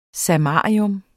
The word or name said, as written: samarium